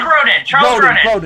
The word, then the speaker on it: Grodin: Charles Grodin, am I saying that right? – Yeah, Charles Grodin, excuse me, yes.